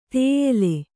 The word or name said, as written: ತೇಯೆಲೆ